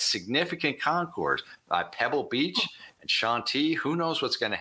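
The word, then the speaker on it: Chantilly: And this car at Shanti, which we are, you know, throw that out at cocktail parties, pronounce it Shanti, everybody will be impressed.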